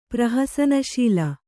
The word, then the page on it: ಪ್ರಹಸನಶೀಲ: kannada